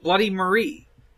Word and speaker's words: Bloody Mary: Bloody Marie. – From Skullgirls? – Okay. From Skullgirls. Sure. – Just to be clear, it's spelled Mary. Says it Marie.